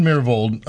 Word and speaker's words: Myhrvold: Nathan Myhrvold, am I pronouncing his last name correctly? – Yes, that's correct.